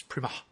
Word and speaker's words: Premat: I believe that's how you pronounce his name, Prima. It's Premat, but he's French, so it's Prima.